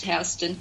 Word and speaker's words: Houston: It's spelt Houston and it's pronounced Houston.